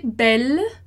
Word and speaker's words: belle: Vai ser belle, porque de qualquer modo, o E final ali, ele não tem som.